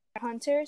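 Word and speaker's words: Hunter × Hunter: Well, it's Hunter, since the X is silent, but, like, yeah.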